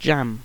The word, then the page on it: jam: British English